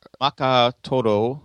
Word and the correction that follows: Makoto Hagiwara: It's Makoto Hagiwara. – Makoto Hagiwara.